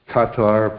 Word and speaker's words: Qatar: Just this past week, Trump went out to Saudi Arabia, Qatar, apparently that's how they pronounce it, and United Arab Emirates.